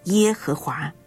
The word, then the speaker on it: 耶和华: 我是自有拥有的，耶和华是我的名直到永远，这也是我的纪念直到万代。我是自有拥有，希伯来文的发音就是耶和华，意思是我是，我所是，或者是我将是我所是。